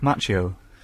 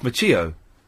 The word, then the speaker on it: Macchio: The spelling, two C's and an H, indicates that the name comes from Italian ancestry, not Spanish, which therefore means that the correct pronunciation is Macchio instead of Macchio.